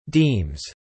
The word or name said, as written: deems